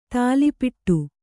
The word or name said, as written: ಥಾಲೀಪಿಟ್ಟು